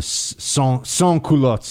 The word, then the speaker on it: sans-culottes: There's an area that had rebelled against the revolutionary government, the government of the sans-culottes, or the sans-culottes. Sorry, I should not pronounce the S at the end.